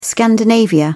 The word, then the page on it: Scandinavia: British English